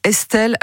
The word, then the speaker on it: Estl: Elle s'appelle Estelle. Alors, petite subtilité, Estelle, ça s'écrit E-S-T-L.